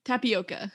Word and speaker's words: tapioca: It's topacio or topacio. I don't know how to pronounce it. I but I know I can't yeah. even. Uh, what is it? – Tapioca. Tapioca. Yeah.